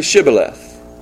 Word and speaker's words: Shibboleth: Say Shibboleth. The problem is that the Ephraimites, because of their dialect, they couldn't pronounce Shibboleth. They couldn't pronounce the SH.